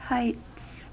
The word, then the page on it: heights: American English